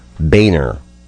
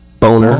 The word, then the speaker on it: Boehner: Some debate on this, but according to the Associated Press, it's pronounced Boehner. John Boehner. – I would have said Boehner or Boner.